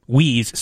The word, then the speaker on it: Wii: It's W-I-I. First of all, W-I-I is pronounced Wii, which emphasizes the social concept that Nintendo envisioned for the console.